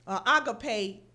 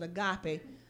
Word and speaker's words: agape: Agape is how it's pronounced. Most people pronounce it agape.